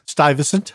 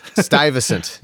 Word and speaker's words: Stuyvesant: Stuyvesant. – Stuyvesant.